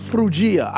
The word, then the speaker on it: Phrygia: Paul and his companions travel through the region of Phrygia. I know it does not look like that's how it sounds, but that's how it's supposed to be pronounced.